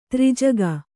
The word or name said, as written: ತ್ರೈಜಗ